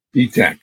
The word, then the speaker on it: Etak: Etak, yeah.